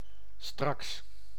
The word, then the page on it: straks: [strɑks]